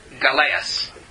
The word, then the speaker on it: galeas: but because you know what they call that in Rome? Galeas. I mean, we would spell it as G-A-L-E-A-S, right?